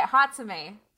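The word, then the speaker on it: Hatsume: Hatsumi. That's how you say it. Hatsumi.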